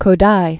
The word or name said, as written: Kodaly